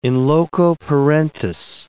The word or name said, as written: in loco parentis